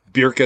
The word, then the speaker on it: Björk: And another one you probably hate is Björk, or Björk, as I should probably call her, pronounced properly in Icelandic.